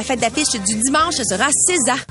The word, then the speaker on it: SZA: Ben oui, César. S-Z-A, si vous l'aviez déjà vu, c'est comme ça que ça se prononce, César.